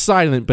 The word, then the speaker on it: SLNT: S-L-N-T. It's pronounced silent, but it's S-L-N-T.